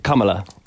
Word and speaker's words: Kamala: Yeah, Kamala.